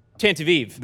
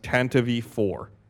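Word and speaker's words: Tantive IV: Tantive Eve. – That it's Tantive four. – Tantive four? – Pablo Hidalgo has said on an official Star Wars material that it is pronounced Tantive four, according to @Ryan_Freese on Twitter.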